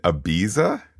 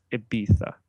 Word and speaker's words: Ibiza: Do you mean Ibiza? – Noobs and the uncultured, such as yourself, pronounce the Z that way. It is actually pronounced Ibiza.